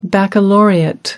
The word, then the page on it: baccalaureate: American English